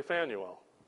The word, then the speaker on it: Phanuel: I think we say it like Samuel, but we say Phanuel.